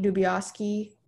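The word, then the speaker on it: Dibiasky: And another scene that I think a lot of people don't think about is the scene where Kate Dubioski, is that how you pronounce her name? Yeah, that's Jennifer Lawrence's character.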